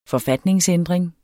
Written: forfatningsændring

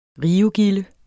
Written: rivegilde

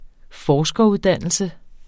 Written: forskeruddannelse

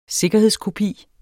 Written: sikkerhedskopi

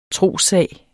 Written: trossag

